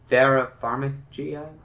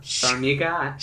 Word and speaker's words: Farmiga: Uh, Vera Farmiga, uh, Farmiga, sure.